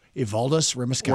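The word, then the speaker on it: Evaldas Rimašauskas: His name is Evaldas Rimaskauskas. Evaldas Rimaskauskas. – We're going to go with that. – Thank you. I said it right the second time.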